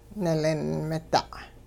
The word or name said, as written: nilin-mida'